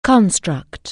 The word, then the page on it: construct: anglais